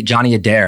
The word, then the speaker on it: Adair: Yeah, so tell me about this guy, Johnny Adair. Am I saying that right? – Yeah.